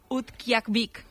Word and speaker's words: Utqiagvik: Utqiagvik. – Hombre, pues lo has dicho genial.